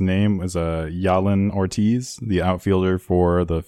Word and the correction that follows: Jalen: I think it's big... Jalen. – Jalen? Okay, Jalen Ortiz.